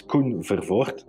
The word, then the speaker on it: Koen Verfoort: My name is real Flemish and hard to pronounce. It's Koen Verfoort.